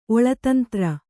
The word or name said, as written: ಒಳತಂತ್ರ